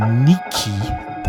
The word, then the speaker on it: NIQH: Next up, another UK producer, Niki. That's how we're told to pronounce N-I-Q-H.